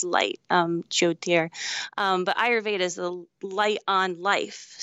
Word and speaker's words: Ayurveda: And that's, so is Vedic like a derivative of oh my God, I'm going to pronounce this wrong. Ayurvedic, whatever it is. You know, my wife said, wow, I butchered that, but you know what I'm saying, right? – Yeah. Ayurveda.